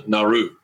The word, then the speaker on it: Nauru: Is it Nauru? Or am I saying it right? – Nauru, right. Nauru. – Yeah, it looks like Nauru.